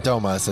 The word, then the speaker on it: Fantomas: Am I even saying that right? – I just thought it was Fantomas. – Fantomas. I think Fantomas is correct.